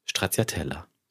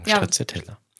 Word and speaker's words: Stracciatella: Sagst du mal korrekt? – Stracciatella. – Okay. – Würde ich sagen, Stracciatella.